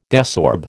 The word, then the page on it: desorb: American English